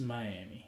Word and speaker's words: Miami: We got our answers in Miami. We got our answers yesterday. – Is it Miami or Miami? – It's Miami.